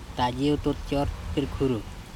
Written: tajiototcOrtirkHuru

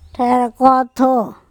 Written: THErkOtho

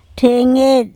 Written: THEngeTl

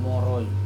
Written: mOrOi

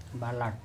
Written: balaT

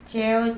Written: ceo

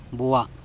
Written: bua

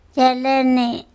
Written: cElene

